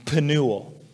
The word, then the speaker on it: Penuel: Now, Phanuel is the Greek pronunciation of the Hebrew name Penuel.